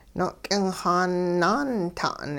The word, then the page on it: no'k'ingxaan-naanta'n: hupa